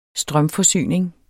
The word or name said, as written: strømforsyning